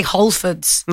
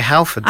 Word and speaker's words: Halfords: You don't say Holfords. – No, I say Halfords. – I say Halfords. – But I pronounce the H, unlike you. I just say Halfords.